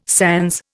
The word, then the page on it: sans: Indian